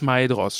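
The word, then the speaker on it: Maedhros: Also, also das also heißt AI Mai würde im Sinn darin nicht wie Ä, sondern wie das AI in Mai ausgesprochen werden. – Okay, also es ist Maidros.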